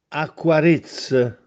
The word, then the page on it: Acquarìzze: Castellanetano